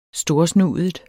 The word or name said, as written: STORSNUDET